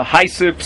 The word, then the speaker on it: hyssop: Okay, there's something called HYSSOP, a hysop, some pronounce it hyssop. It's in the parish of Taras HaMetzora.